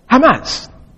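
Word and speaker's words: Hamas (חמס): Some will write it as C-H-A-M-A-S, but because it's what it's a, more or less a guttural sound in the Hebrew, just like ka sound. Here, some people will spell it this way, just like H-A-M-S. And now when we spell it like that, you see what it sounds?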